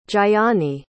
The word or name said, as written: jayani